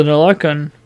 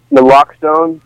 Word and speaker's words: Naloxone: Now the Naloxone. No, I'm not saying that right. Narcan? – Naloxone. The name is Narcan.